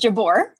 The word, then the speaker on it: Jabbour: I hope I'm pronouncing it right. Yeah, Mr. and Mrs. Jabor. – That's perfect. That's great.